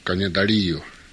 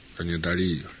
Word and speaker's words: Kanyadariyo: By the way, that name is a Mohawk word. And the right pronunciation is Kanyadariyo, which means beautiful lake, Kanyadariyo. They corrupted it to Ontario.